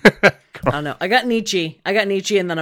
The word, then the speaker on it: Nietzsche: I know it's Nietzsche.